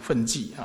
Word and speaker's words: 分际: 这两个字读成分际啊，分际呢就是界限，边际没有分际啊，没有界限，没有边际啊，无有分际相，骗满故，没有这个边际，没有界限。